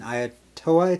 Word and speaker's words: Ayutthaya: And I actually rode elephants twice, once in Nepal and then once in Ayutthaya, it's pronounced. It's the...